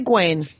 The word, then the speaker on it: Gawain: Sir Gawain and the Green Knight, yes. – Good for me. Yeah. All right, because I always want to say Gawain, because I'm from Wisconsin.